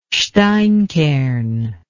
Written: steinkern

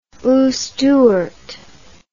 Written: Ustyurt

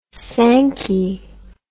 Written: Sankey

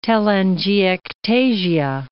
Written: telangiectasia

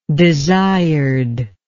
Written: desiredness

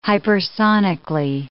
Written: hypersonically

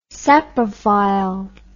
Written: saprophile

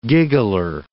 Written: giggler

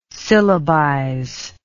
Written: syllabize

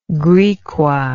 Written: Griqua

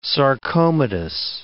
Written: sarcomatous

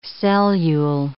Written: cellule

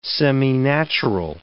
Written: seminatural

